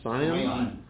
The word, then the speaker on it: scion: That's known as a S-C-I-O-N. How do you pronounce that? Scion? – Scion. – Like the car.